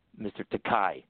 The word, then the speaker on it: Takei: This is the first or second convention, and I said, "Oh, nice to meet you, Mr. Takei," and he said, "No, it's okay, like Takei, you know, Takei, okay," and that's when I first met George.